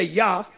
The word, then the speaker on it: Jah: And we say Yah because there's no J in the Hebrew language. So the J is pronounced Y.